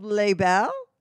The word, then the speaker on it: Les Belles: Yeah, a collant business called Les Belles.